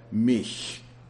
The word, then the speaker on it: mich: So while you have a word like Bach that has that hard CH, you've got a word like mich, which means me, that has a soft CH. But I couldn't say it. And she finally made me realize that it's the same as the English H when it's followed by a long U, like the word huge.